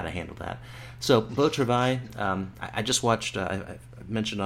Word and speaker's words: Beau Travail: Beau Travail? – That's fine. Yeah. Yeah, I mean, okay. The only difference is you would need to pronounce the R a little bit like a...